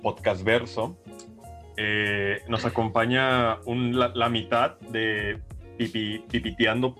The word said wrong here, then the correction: Pipeteando: ciencia, si lo dije bien? Sí, pipeteando. Ah. pipeteando.